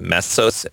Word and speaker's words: Mesos: Supposedly they're using something called Apache, I think you pronounce it Mesos, M-E-S-O-S, and also something, believe it or not, called Jarvis.